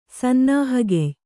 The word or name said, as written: ಸನ್ನಾಹಗೆಯ್